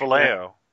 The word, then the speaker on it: Vallejo: Well, there were the glasses, but I think it's – I don't want to get his name wrong, but Boris Vallejo. – I think it's Vallejo. – Vallejo.